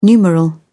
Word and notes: numeral: British English

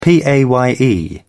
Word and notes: paye: British English

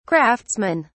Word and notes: craftsmen: British English

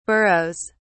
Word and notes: burroughs: British English

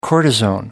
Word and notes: cortisone: American English

